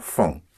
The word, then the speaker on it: font: for the third person plural, for they, you don't have the silent ENT, but a one syllable ONT. En son vont font.